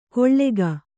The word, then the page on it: ಕೊಳ್ಳೆಗ: koḷḷega